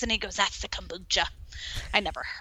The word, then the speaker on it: kombucha: You're definitely saying it right. So it's kombucha with an O. You get people in the Midwest going kombucha and they spell it with an A, but that's not exactly right.